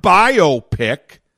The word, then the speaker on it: biopic: But when there's no hyphen there, you pronounce the word differently, phonetically, because now it's not two separate words connected, it's one word. And accents are in different places. So instead of biopic,